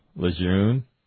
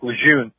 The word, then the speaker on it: Lejeune: Yeah, I remember that when I was active, I'd say, like, Lejeune. And then someone's like, Lejeune. I say, well, I'm not French. I'm American. I pronounce it Lejeune.